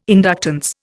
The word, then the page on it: inductance: Indian